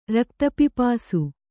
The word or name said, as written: ರಕ್ತಪಿಪಾಸು